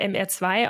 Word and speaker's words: MR2: Und zwar, weil MR2 auf Französisch ausgesprochen MR2 ist. Und das klingt dann relativ doll nach Merde, wenn man es ein bisschen schneller ausspricht, was man dort ja zum Fluchen benutzt.